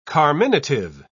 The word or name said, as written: carminative